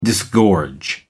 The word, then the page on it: disgorge: American English